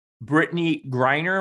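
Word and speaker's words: Brittney Griner: So you have WNBA star Brittany Griner.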